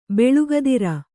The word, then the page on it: ಬಳಗಧಾರೆ: baḷaga dhāre